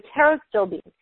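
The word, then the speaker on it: pterostilbene: And then another one is taro still bean. And it actually starts with a P, but you don't really pronounce it. So taro still bean.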